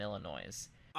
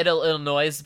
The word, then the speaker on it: Illinois: Did I say Illinois by accident? – Yes, you did. And I do want to tell you that S is a ghost S. You do not pronounce it.